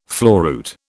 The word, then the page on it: floruit: British English